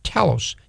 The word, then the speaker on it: telos: The word end here is a Greek word pronounced telos, T-E-L-O-S.